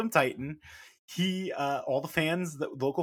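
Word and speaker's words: Titán: With Angel De Oro and Cavernario and Teton, it says from the Observer. – Yeah, title. Yeah. Which is, by the way, and apparently we've been wrong, it's Titan. It is pronounced Titan in CMLL.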